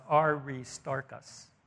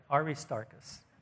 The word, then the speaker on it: Aristarchus: And it says here in verse 10, and his name is pronounced Aristarchus. All right, Aristarchus. So that's R-I, is it, you know, long R-I, whatever.